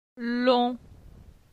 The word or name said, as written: Lon